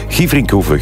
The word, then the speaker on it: Gieverinkhoeve: Benoit Dornay stuurt, hey Björn, de rally is in Gieverinkhoeve, zo spreek je het uit. Gieverinkhoeve, groetjes, de papa van Jules.